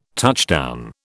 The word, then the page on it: touchdown: British English